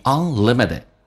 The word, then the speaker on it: unlimited: Retain A 饱满一点， Retain knowledge may just be unlimited 无限的， Unlimited 最后一个 T 发 D 这个发音， Unlimited。